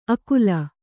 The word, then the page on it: ಅಕುಲ: akula